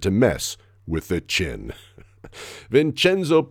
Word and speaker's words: Vincenzo: By the way, he did have something of a Jay Leno jaw, but he got the nickname from his mom, who always called him Vincenzo and put a hard accent on that middle syllable.